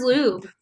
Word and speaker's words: Ljub: It's at Alyssa Ljub. It's AlyssaLjub. – But it is pronounced Ljub. – It is pronounced Ljub.